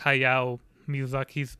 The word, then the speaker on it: Hayao Miyazaki: Like it's not like Hayao Miyazaki's been like against it.